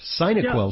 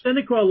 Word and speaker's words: Cinequil: First of all, first things first, am I pronouncing it correctly? It's Cinequil, right? Because Cinequil suggests it might be something for your sinuses or nasal passages. – No, Cinequil, like synapse.